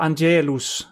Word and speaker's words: Angelus: On the weekly Angelus address, or Angelus, I want to pronounce it more like Latin.